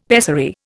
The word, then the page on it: pessary: Indian